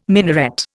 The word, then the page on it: minaret: Indian